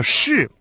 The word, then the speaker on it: 是: Обратите внимание на то, что связка «ши» произносится с четвертым тоном, так же, как и отрицание «бу». Поэтому перед связкой это отрицание по общему правилу получает второй тон.